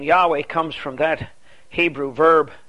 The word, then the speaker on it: Yahweh: Yahweh, often mispronounced Jehovah.